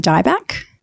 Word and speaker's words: Dyback: It is my full name, Samantha Dyback.